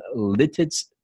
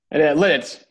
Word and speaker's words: Lititz: He's originally from Lititz, PA. Am I saying that correctly? – Lititz, but you got the right idea.